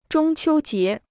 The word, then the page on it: 中秋节: zhōng qiū jié